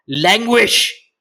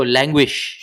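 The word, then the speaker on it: Langewiesche: Okay, so he wrote, probably pronounced languish. – How's it spelled? – L-A-N-G. – L-A-N-G. – E-W. – E-W. – I-E. – I-E. – S-C-H-E.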